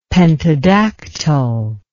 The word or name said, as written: pentadactyl